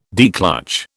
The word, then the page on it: declutch: British English